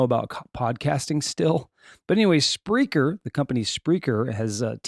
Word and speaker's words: Spreaker: Okay, I, I pulled this little little uh, article here, and they spelled it speaker and it's not Speaker. The company's name is Spreaker.